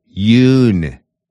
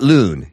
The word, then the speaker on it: Yoon: South Korea's president is Yoon, with a Y, Yoon. Not loon. He's not a loon. You're the loon, Biden.